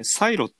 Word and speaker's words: CIRO: で、まあ、この DFS、防衛省電波部以外によく知られてるのが、内閣情報調査室、これ、サイロって読むんですけど、 CIRO ですね。